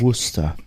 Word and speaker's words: Worcester: Aber Worcester, einfach W-U-S-T-E-R. So sprechen die das aus.